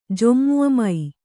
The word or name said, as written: ಜೊಮ್ಮುಮೈ